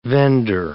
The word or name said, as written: vender